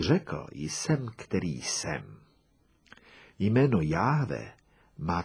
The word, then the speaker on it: Jahve: Proto existuje jedna nesprávná výslovnost Jehova a správná výslovnost Jahve.